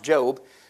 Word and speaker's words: Job: It's pronounced, or it's spelled like Job, but it's pronounced Job. But we do know that it is a long O sound, and so we accept that. So the name Job is how it is pronounced.